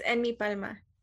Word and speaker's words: Enmipalma: It's Enmipalma.